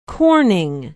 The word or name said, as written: Corning